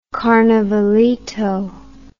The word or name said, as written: carnavalito